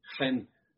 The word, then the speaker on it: chen: These being, as English words, they're spelled ches and chesed, but I think they're pronounced chen and chesed.